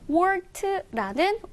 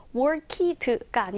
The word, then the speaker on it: worked: 자, 여기에서 보시면 worked라는 work의 과거형이네요. 발음은요. worked가 아니라 worked. worked로 원어민들은 발음을 해요.